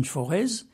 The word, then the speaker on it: Forez: Ici, cette fois, dans le forêt, qu'on prononce en Auvergne forez.